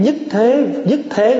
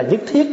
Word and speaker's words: nhất thiết: Nhất thế là nhất thiết đó.